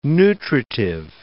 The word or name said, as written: nutritive